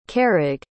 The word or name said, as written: carraig